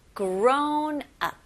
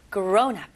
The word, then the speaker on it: grown up: 그리고 강세는 앞부분. Grown up가 아니고요. Grown up. Grown up.